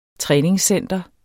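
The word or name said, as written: træningscenter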